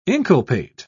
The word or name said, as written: inculpate